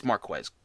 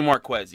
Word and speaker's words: Marquise: I keep calling him Marquise. It's Marquez. Get get yourself together. Jeez. It's definitely Marquez, even though it's spelled Marquise.